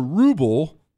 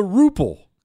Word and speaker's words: ruble: Shogun, Russia has the ruble, not the ruple. Quit saying it wrong on live radio, you hillbilly.